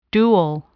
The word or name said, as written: dual